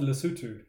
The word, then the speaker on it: Lesotho: We pronounce it Lesotho.